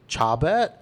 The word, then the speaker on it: Chabert: Lacey Chabert as Gretchen Wieners. Is that how it's pronounced? – Yeah, I learned that Chabert today.